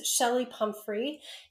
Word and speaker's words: Chelli Pumphrey: It's Shelly Pumphrey, and my name is spelled Chelli Pumphrey. So sometimes people look for the Shelly with the S, but no, I'm the Shelly with the C.